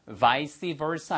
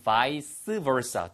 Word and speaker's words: vice versa: 그래서 vice versa 아니면 vice versa 두 가지 발음 가능합니다.